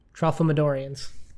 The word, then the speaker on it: Tralfamadorians: did I say it right? – Tralfamadorians. – I was close.